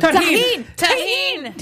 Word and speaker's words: tagine: Even tagine. I said it wrong. – Tagine! – Damn it, God! – Are you Polish? What's happening? – Two white people on the show. You gotta throw in the silent J. – Tagine. Yeah.